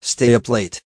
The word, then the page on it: stay up late: American English